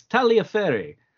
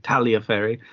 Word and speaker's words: Tagliaferri: It's Taliaferri. – Taliaferri. – T-A-G-L-I-A-F-E-R-R-I. Taglia, usually you leave out the G, you don't, it's not a hard G. Taliaferri.